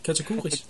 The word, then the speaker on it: Katakuris: Katakuris, ja.